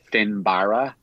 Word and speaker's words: Finbarra: Yeah, like if you wanted to be very true, it's like Finbarra.